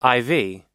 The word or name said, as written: iv